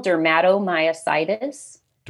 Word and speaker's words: dermatomyositis: Juvenile dermatomyositis. – Dermatomyositis. – So derma, skin, myo, muscle.